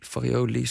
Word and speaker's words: Farioli: Potter en Farioli, zeg ik dat goed? – Ja.